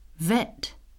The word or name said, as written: vet